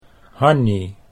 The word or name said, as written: Hannie